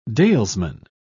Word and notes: dalesman: British English

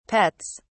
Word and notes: pets: British English

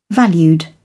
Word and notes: valued: British English

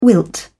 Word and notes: wilt: British English

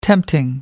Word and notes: tempting: American English

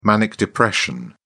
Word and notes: manic depression: British English

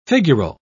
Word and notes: figural: British English

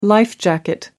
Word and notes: life jacket: American English

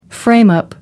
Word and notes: frame up: American English

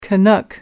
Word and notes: canuck: American English

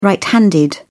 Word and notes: right-handed: British English

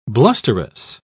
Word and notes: blusterous: British English

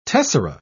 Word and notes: tessera: British English

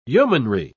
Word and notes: yeomanry: British English

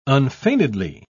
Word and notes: unfeignedly: British English

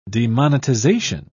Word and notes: demonetization: British English

